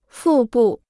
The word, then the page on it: 腹部: fù bù